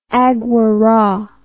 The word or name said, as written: agouara